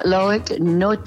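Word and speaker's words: Loïc Nottet: help me with pronunciation here, BJ, Loic Nottet. – No, Loic Note. – Loic Note. – It looks like Nate, but it's Loic Note.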